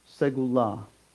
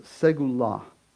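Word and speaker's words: segulah: It's pronounced segula. Oh, it's not coming up on the screen right, so I'm sorry. It's pronounced segula.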